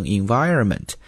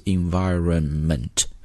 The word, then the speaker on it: environment: 后面的 environment 也是要注意一下它的发音，中国同学有的时候会发的比较的含糊，直接读成 environment。 No, there's an N sound there, so it's environment, OK?